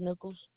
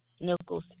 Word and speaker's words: Nichols: Jaquetta Nichols. – Spell that for me. First name. – J-A-Q-U-E-T-T-A. Last name, Nichols. N-I-C-H-O-L-S.